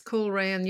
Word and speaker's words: Coleraine: You refer to being at a disco in 1976 and hearing about the bombs in Coleraine, if I've got the right pronunciation. – Yes, Coleraine.